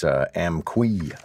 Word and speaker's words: Amqui: who says that I'm getting the pronunciation of the town where this incident happened in Quebec wrong. So it's Amqui, apparently.